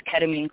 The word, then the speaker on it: ketamine: And then how can, I think it's called ketamine, I hope I'm pronouncing it correctly.